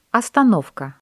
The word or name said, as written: остановка